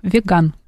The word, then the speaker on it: веган: Веган. – Веган. Угу. Веган. На А, значит, угу.